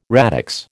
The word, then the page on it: radix: American English